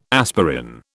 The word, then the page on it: aspirin: British English